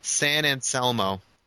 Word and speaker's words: San Anselmo: San Anselmo.